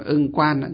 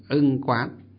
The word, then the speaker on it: ưng quán: Chúng ta hay đọc là ưng quan, nhưng thực tế nó là ưng quán.